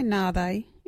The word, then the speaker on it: nhaathayi: Our local Wadjuri word is nathe. What is nathe? Nathe is when one cannot remember the proper name for something. You can say nathe in this sentence.